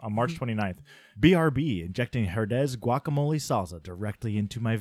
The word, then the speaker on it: Herdez: For the listeners, I know enough Spanish to know how that would be pronounced, Herdez.